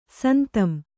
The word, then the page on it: ಸಂತಂ: santam